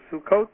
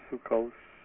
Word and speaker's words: Sukkot: And the Dvar Torah is about Sukkot or Sukkos or Sukkos, depending on where you come from and your pronunciation.